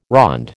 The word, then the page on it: ronde: American English